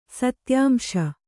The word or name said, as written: ಸತ್ಯಾಂಶ